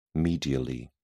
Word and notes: medially: British English

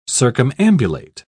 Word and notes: circumambulate: British English

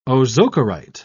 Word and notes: ozocerite: British English

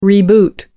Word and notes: reboot: American English

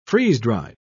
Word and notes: freeze-dried: British English

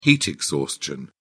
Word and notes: heat exhaustion: British English

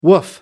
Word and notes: woof: American English

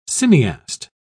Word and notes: cineast: British English

